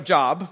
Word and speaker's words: Job: It looks like Job, but it's pronounced Job.